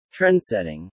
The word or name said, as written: trendsetting